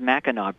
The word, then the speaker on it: Mackinac: pronounced Mackinac Bridge, actually.